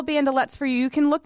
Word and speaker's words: bandelettes: So bandolette is spelled B-A-N-D-E-L-E-T-T-E-S, bandolettes.